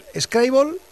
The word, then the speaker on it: Scrabble: El actual campeón del mundo de Scrabble, Scrabble. Sí. – Esa es la pronunciación correcta, ¿no? – Bueno, yo creo que es la más adecuada.